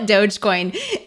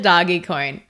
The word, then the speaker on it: Dogecoin: And basically, I call it Dogecoin. Nicole calls it doggy coin. The jury's out. But doggy coin honestly sounds more fun. So I'd be happy with moving forward with that pronunciation.